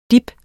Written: DIPPENE

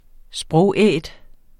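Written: SPROGÆT

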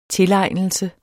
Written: tilegnelse

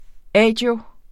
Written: agio

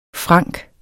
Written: frank

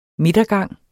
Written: midtergang

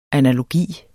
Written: analogi

